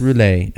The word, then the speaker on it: rouler: And it's roulé. – Yeah. – He was mispronouncing it on my Google Translate. Let the good times roll. And it was... Laissez le bon temps rouler.